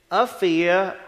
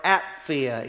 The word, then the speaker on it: Apphia: and to Apphia or Apphia, either pronunciation is correct, our sister, and to Archippus, our fellow soldier.